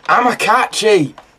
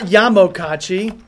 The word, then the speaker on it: Amokachi: Amakachi! – That's what it was, not Yamokachi. Finally. – What's it? How do you spell it? How do you spell it? – Amokachi. I added a Y.